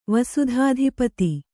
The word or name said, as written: ವಸುಧಾಧಿಪತಿ